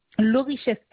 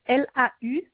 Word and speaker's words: Laurichette: Alors, mon nom de famille, c'est Laurichette, LAU. Riche2e, le prénom Laetitia. Et j'habite à 3 cités Cherchefeuille. – Alors, le nom s'écrit comment? – Laurichette, LAURICHETTE.